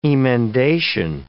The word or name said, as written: emendation